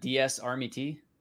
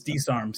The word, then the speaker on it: DSARM: DSARMET? – It's DSARM.